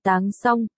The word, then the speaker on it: Tăng Song: Anh họ trong điện thoại anh Doãn là Tăng Song, đọc là Táng Song, hay, đường chung này là Tăng Trông, nghĩa là bình đựng đường, làm nhàm.